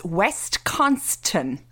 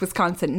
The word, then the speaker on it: Wisconsin: that Wisconsin is pronounced Wisconsin, not West Conston.